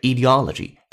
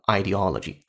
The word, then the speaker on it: ideology: 原声读的是 ideology，它也可以读成 ideology，都是可以的。